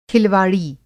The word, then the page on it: खिलवाड़ी: Hindi